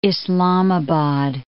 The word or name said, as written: Islamabad